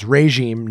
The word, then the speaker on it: regime: The first syllable is sounded as ray. So it's regime, not regime.